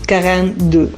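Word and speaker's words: quarante-deux: Voy a hacer un 42, ya que el 42 en francés se pronuncia así más o menos: quarante deux.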